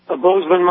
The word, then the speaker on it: Bozeman: Bozeman, Montana.